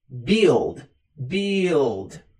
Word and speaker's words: build: Build, build, build. Build significa construir. Então, I build, eu construo. I build. Preste atenção na pronúncia, não é build, não, é build, build.